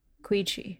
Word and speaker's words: Quechee: Queechee is spelled Q-U-E-C-H-E-E. You might think Queechee or Queechee, but no, it's Queechee.